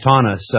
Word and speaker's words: Taunus: You spell it T-A-U-N-U-S, Taunus, I believe would be the pronunciation, Taunus, part of the Deutsche Bank AG system.